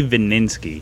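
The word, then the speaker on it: Vininski: Vininsky. My last name. – You might have to spell that for us. – Yeah, V as in Victor, I-N-I-N-S-K-I. It's spelled exactly how it sounds.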